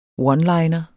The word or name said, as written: oneliner